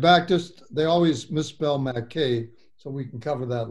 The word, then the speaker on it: Mackay: We got to make sure we get that right. – Mackay. – That's right. – Okay. – Mackey is the way you spell it. That's perfect. Mackay.